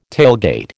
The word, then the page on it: tailgate: American English